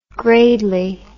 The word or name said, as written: gradely